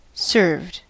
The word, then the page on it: served: American English